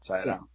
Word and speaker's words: Sydow: I was looking at the credits, and Max von Sydow, I don't even know if that's how you say it. Sydow. – Sydow. – Yeah, Father Marin.